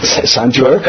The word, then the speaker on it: santur: Am I pronouncing it correct, santur? – Absolutely correct.